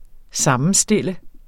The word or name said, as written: sammenstille